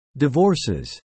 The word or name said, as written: divorces